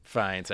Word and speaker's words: Fiennes: It's Ralph Fiennes, actually. – Fiennes. – I did look it up when I was doing a radio spot. I'm like, I got to make sure I do it correctly. And it's actually Ralph Fiennes, even though it's spelled like Ralph.